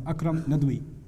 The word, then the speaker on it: Akram Nadwi: Akram Nadwi. A-K-R-A-M. Akram Nadwi. That's how you say it in Siri. Nadwi, N-A-D-A-W-Y, or N-A-D-W-I also.